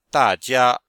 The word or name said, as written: da4jia1